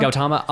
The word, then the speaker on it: Gautama: Gautama.